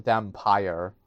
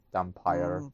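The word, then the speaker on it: Dhampir: It can also be pronounced a dampire. Or with like a PYRE, like pyre, dampire thing going on.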